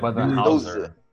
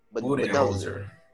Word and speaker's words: Budenholzer: Mike é Budenholzer. Budenholzer.